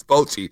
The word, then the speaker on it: Bochy: Is Boucher Texas World Series the bonus we win? It's Bochy. Oh, the Bochy. Bochy.